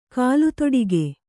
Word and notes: ಕಾಲ್ದೊಡಿಗೆ: kāldoḍige

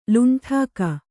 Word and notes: ಲುಂಟಾಕ: kannada

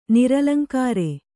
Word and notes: ನಿರಲಂಕಾರ: niralaŋkāra

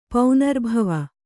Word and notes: ಪೌನರ್ಭವ: paunarbhava

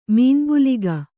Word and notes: ಮೀನ್ಬುಲಿಗ: Kannada

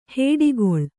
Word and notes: ಹೊಡೆಗುಳು: kannada

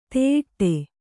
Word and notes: ತುಯ್ಯಾಟ: tuyyāṭa

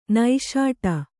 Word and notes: ನಿಷ್ಠ: niṣṭha

